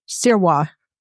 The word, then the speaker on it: Sirois: So Dr. Sirois? – Sirwa. – Sirwa. Okay. – Yeah, French. Yeah, exactly.